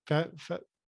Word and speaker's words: Feyenoord: but PSV and Feyenoord? – Feyenoord. – Feyenoord, yeah.